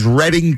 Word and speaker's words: Reading: Now, the pronunciation is Reading Terminal. Right? – Reading Terminal.